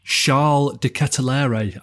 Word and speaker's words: Charles De Ketelaere: I, believe you say Charles de Ketelaere.